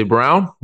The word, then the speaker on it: Braun: I don't know why they say Brown, but I mean, spelled like Braun, but Christian Brown off the bench played a huge role for them.